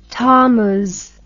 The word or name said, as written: thammuz